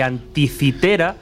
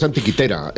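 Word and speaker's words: Antikythera: Pues-, pues, pues, en griego es antiquitera. Eh, y anticitera es porque, como lleva una K y una H, la solemos.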